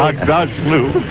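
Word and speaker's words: Aghdashloo: Agdashlu.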